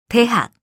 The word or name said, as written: 대학